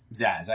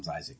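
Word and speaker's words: Zsasz: Is he supposed to be Zaz? Because people made Arkham City and Arkham Knight, mm-hmm. they pronounce it Zaz. – Yeah, Zaz. Yeah. – Even Zaz. – Batman pronounces it Zaz in the games.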